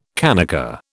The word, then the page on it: kanaka: British English